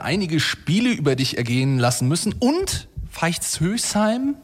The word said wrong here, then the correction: Veitshöchheim: wenn ich es jetzt richtig ausgesprochen habe. – Veitshöchheim. – Veitshöchheim.